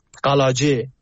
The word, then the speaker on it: قلاجه: یا روستاهای اطراف مریوان، چند تا شگه اسم ببرم، روستای بالک، روستای نجمار، روستای دزلی، روستای بهرام آباد، قلاجه تلفظ به کردیشه، قلعجی فارسی می نویسن، و روستای اطراف بانه، همون باینجان، بلسن، آلود، اینا خب از روستاهایی بود که می تونم بگم بمباران شیمیایی شده بودن.